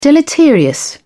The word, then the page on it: deleterious: British English